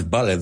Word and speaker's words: vulture: Que muchos dicen vulture, pero no es vulture. Vulture, correct? Vulture. Y tiene la T-U, es que es ch.